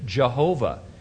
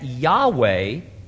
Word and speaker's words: Yahweh: They do not like the pronunciation Jehovah. They consider it an erroneous and disrespectful Christianized version of the true biblical name of God. Still others say the pronunciation was never lost and that Yahweh is the correct pronunciation.